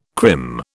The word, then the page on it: crim: British English